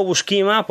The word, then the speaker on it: Buscema: Digo Buscema porque es la forma de pronunciarlo correctamente, pero lo siento mucho, pero son muchos años llamándolo John Buscema y lo voy a seguir llamando John Buscema.